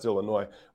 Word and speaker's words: Illinois: First off, it's pronounced Illinois. They don't say the S, but welcome other people, especially from out of state, to pronounce it Illinois, which is just hilarious.